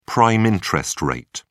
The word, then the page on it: prime interest rate: British English